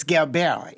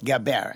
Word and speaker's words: Gilbert: That's exactly. It's Gilbert. It's French. – Oh. – Gilbert.